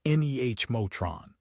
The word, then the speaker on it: Nemotron: Google's rapid expansion of its Gemma model family, which has already hit 200 million downloads, and NVIDIA's focus on its Nemotron, pronounced NEH-motron, family show that the market is starving for specialized domestic alternatives.